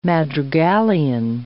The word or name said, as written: madrigalian